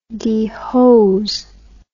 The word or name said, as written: dehose